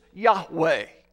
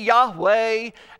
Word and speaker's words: Yahweh: There are no vowels in Hebrew, only consonants, so we guess at the pronunciation. Our best guess is Yahweh, often translated in our Bibles as the Lord or I am who I am.